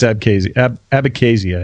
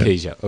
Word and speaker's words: Abkhazia: I think it's Abkhazia, I think is how you say it. – Abkhazia, okay, Abkhazia.